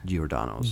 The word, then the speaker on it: Giordano's: Giordano's.